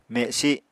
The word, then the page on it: mesi: Apatani